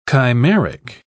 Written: chimeric

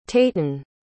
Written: tayten

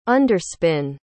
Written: underspin

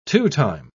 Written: two-time

